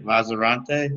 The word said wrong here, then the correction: Lanzarote: Yeah. Yeah. – So how long were you there with them for? – Yeah, I played there these past two seasons. I played in Lanzarote.